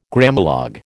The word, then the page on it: Grammalogue: American English